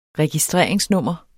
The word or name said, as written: registreringsnummer